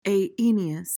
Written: aeneous

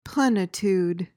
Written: plenitude

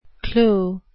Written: clou

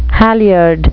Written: halyard

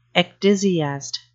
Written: ecdysiast

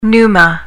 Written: pneuma

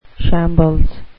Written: shambles

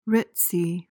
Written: ritzy

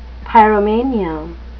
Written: pyromania

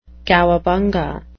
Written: cowabunga

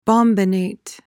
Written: bombinate

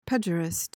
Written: pejorist

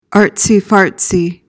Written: artsy-fartsy

